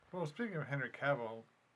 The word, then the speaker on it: Cavill: Yeah, so, Henry Cavill. Cavill. How do you say his name? – Cavill. – Cavill, yeah.